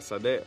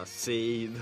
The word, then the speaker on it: Sade: sì, infatti forse si pronuncia Sade o Sade, non Seid, all'americana maniera come l'hai pronunciato.